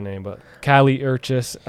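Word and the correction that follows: Kali Uchis: That's Uchis, right? – Uchis, yeah, Callie Uchis, yeah.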